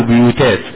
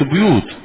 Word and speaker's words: بيوت: ينفع، اللي هو جمع الجمع، اقول بيوتات هو جمع بيت، بيوت، في بيوت، وتكسر تضم الباء، هذا هو النطق الصحيح، مش بيوت، لا بيوت،